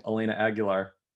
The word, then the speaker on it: Elena Aguilar: Elena Aguilar correctly. And then Rye rightly pointed out that I could have just thought of Christina Aguilera and it would have been really easy.